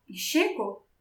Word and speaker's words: Chico: C-H-I-C-O. – Oh, okay. That's, yeah, okay. I would pronounce that very differently than what you just did. If I'm reading that on a piece of paper, I am not saying anything that remotely sounds like sugar.